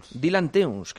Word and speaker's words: Teuns: Dylan Teuns, que es belga, Dylan Teuns.